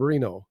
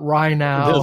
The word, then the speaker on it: Rienow: We probably ought to tell people, you know, he spells his name R-I-E-N-O-W, but it's pronounced Reno. And people that see the name don't often pronounce it right the first time. We want to help him by getting it right. – It's not Rhino. – It's not. No. No. – No, it's Reno. And although it's not like Reno, Nevada.